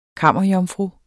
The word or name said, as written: kammerjomfru